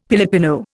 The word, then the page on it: Pilipino: Indian